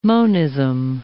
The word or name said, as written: monism